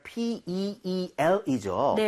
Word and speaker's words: peel: 자, 발음할 때 PI가 아니라 PEEL이죠. – 네. – So 입모양을 살짝만 옆으로 벌려주면 좋겠습니다. So PEL 이렇게 하지 마시고요. – 네. – 그렇게 하면 알약이란 뜻이잖아요.